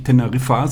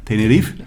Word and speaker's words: Tenerife: Und die sagen ja auch nicht Teneriffa, sondern Tenerife.